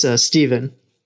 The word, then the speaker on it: Stevan: It's spelled Stiofan. – And will you pronounce that phonetically, please? – Yes, it's Stephen.